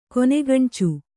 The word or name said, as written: ಕೊನೆಗಣ್ಚು